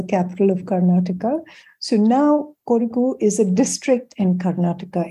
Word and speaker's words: Kodagu: How do I pronounce the name of the land? – Kodagu. – Okay. So Kodagu.